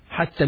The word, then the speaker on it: حتى: حتى, يا مقصورة, which is pronounced as an ألف. حتى, until. Until what? Let's see. Hatta means until.